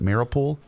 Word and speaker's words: Mariupol: Is it Mariupol? – Yeah. – That mayor is claiming that there are nine thousand civilians being buried by soldiers, and hundred thousand people still trapped in Mariupol.